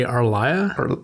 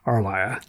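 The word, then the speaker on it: R'lyeh: I R-L-Y-E-H. I never know how to wrap my tongue around that one. – I always say Arlia. – R is interesting as a letter in English. It's a semi-vowel. You can pronounce R all by itself. It doesn't need a vowel on either side of it to become a vowel. So I just give it the vowelness. Arlia.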